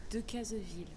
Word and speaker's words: Decazeville: Decazeville.